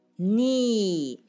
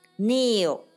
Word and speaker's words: knee: 我们看一下 K N 的组合，很多单词，knee、 knee 膝盖。注意这里的 K 不发音，直接发 N 音。 kneel 加个 l 是跪下，动词。